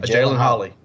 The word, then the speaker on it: Jalen: Pronounce that first name. Is it a Halen, a Jalen, a Jalen Holly? – A Jalen Holly. – Okay, I wasn't sure if that J was silent,